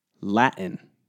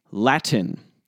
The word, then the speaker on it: Latin: Since I've started this podcast, I've consistently received emails criticizing my pronunciation of a certain word. That word is, some of you already know what's coming, get ready for it, Latin. Apparently, I should be pronouncing this word as Latin. The general theme of these emails is, I love the show, but you've got to put the T back in Latin.